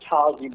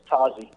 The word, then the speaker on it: Tazi: Pronounced Tazi, by the way, Mike. – Tazi? – Yes, Tazi. Sounds with an O.